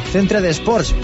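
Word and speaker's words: Centre d'Esports: al Centre de Sports, por pronunciarlo un poquito ahí poniendo el acento catalán, aunque no me salga muy bien.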